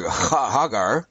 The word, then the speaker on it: Ghaggar: and the first letter is uh, mute. So the GH sound is actually pronounced uh, kind of like Hagar.